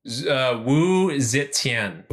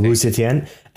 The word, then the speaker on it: Wu Zetian: Wu Zetian. – Well, Wu Zetian.